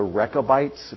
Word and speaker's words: Rechabites: We'll see a guy who comes from a group called the Rechabites, if you can't spell that. Let me spell it for you. R-E-C-H-A-B-I-T-E-S. R-E-C-H-A-B-I-T-E-S.